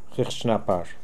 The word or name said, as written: Hikhdjnapar